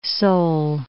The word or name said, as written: Seoul